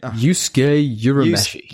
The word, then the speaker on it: Yusuke Urameshi: Yusuke Urameshi.